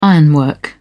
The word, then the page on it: ironwork: British English